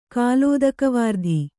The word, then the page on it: ಕಾಲೋದಕವಾರ್ಧಿ: kālōdakavārdhi